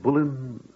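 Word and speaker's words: Boleyn: Oh, that is the right way to say her name, isn't it? – Bullen, Bullen.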